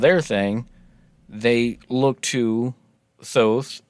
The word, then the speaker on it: Thoth: But you read about Thoth before, right? T-H-O-T-H is, I mean, phonetically, American phonetically, that's how you pronounce it, but the narrator pronounced it Tote. – Yeah, Tote is what I've heard.